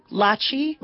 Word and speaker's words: Lachi: So my name is Mubin, and I just want to check I'm saying your name correctly. – Lachi like Versace, girl.